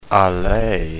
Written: Allais

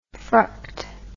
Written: fruct

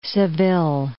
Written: Seville

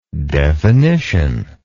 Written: definitionally

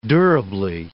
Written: durably

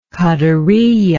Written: qadariyah

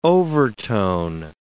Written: overtone

